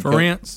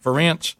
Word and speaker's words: Ferentz: They also demand that athletic director Gary Barta, and I don't know how you say Kirk's last name, the head Ferentz. – Coach. Is it Ferentz? How you say it? Ferentz.